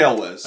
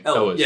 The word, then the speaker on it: Elwes: Cary Elwes. – Amazing. Elwes.